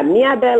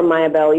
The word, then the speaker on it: Miabel: Yeah, Miabel or Mayabel, either, either. I think Mayabel would be the English pronunciation.